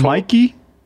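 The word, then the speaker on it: Michie: It's pronounced Mikey? – Yeah. – Oh. – Blown mind over here.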